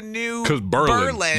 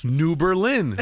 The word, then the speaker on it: New Berlin: And I had to explain to them that I think New Berlin mispronounces it, and I had to readjust when I moved here 25 years ago. – It should be pronounced New Berlin, but it's New Berlin. – It's so hard. – It really is mispronounced, and my wife technically pronounced it the right way, but I got to correct her to say it the wrong way for Berlin.